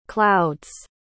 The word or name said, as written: clouds